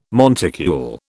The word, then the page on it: monticule: British English